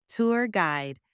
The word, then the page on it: tour guide: anglais